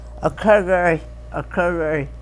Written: o krgry

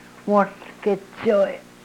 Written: wohlkechoy

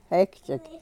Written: hekchek

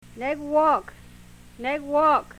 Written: newook

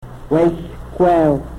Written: wehlkwew

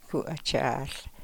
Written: ku o chaahl

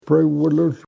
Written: prewrlrhl

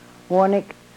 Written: wonik

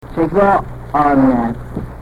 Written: chegoonep

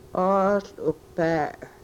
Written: oohl u-par

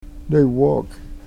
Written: newook

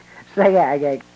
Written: segaageyek

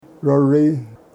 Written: rori

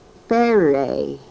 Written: perey

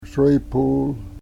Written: srepul

